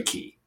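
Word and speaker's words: kei: And that is K. It is actually key. Everything else was perfect, but they are key cars.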